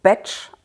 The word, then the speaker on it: Bach: Genauer gesagt, der Name wird eigentlich Batch ausgesprochen, wird aber wie das deutsche Wort Bach geschrieben. Deswegen sagt man in Deutschland Bach, weil er bzw. seine Familie sind Waliser gewesen und da wird halt Bach, also diese vier Buchstaben, als Batch ausgesprochen.